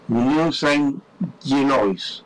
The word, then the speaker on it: Union Saint-Gilloise: Union Saint-Gilloise, así se pronuncia.